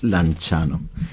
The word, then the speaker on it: Lanciano: Se pronuncia Lanciano.